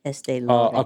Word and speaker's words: Estee Lauder: Tom Ford reaches billionaire status after Estee Lauder. Hope I said that right. – Estee Lauder. – Lauder. Okay.